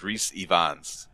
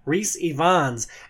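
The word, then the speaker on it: Rhys Ifans: It's actually Ivans, but it's I-F-A-N-S, but you pronounce it with the V instead. So it's Reese Ivans. – Reese Ivans as Rasputin in The King's Man.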